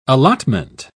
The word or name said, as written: allotment